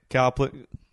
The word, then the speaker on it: Copeland: It says, what is Kenneth Copeland? How do you pronounce Copeland. – It? – Copeland. Kenneth Copeland.